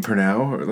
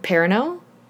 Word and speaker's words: Perrineau: Am I saying Pernow? Like, Perrineau? Is that how you say it? – I think so.